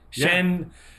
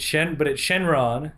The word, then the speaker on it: Shenron: Shenlong. How they say it in the subtitles. But apparently it's Shenron. – Shenron in the dubbed.